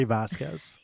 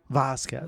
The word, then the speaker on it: Vasquez: Uh, we have our voice of reason back, one of the originals, the OG Patti Vasquez. Did I say that right? Vasquez.